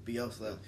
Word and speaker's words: Bielsa: Bielsa.